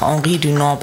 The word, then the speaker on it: Henri Dunant: Das bedeutet, dass die Straße nach Henri Dunant benannt wurde. Das wird französisch ausgesprochen, aber der Mann kommt eigentlich aus der Schweiz.